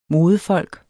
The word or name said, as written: modefolk